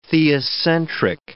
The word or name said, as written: theocentric